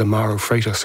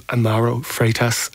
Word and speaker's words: Amaro Freitas: It turns out it was Amaro Freitas who you just heard right there, and the conversation would have been a whole lot different.